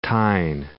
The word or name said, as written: Newcastle upon Tyne